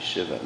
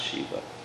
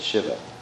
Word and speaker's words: Shiva: It's actually Shiva, not Shiva. just tell you all about the proper pronunciation. It was, if it was Shiva, that actually is the name of a jackal in Hindi. So it's Shiva, just because it's a short I.